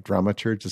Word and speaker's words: dramaturge: You're the director of Pomegranate, but you, have also said you're the dramaturge for. Am I saying it right? Dramaturge is such a great word. – Dramaturge, yes.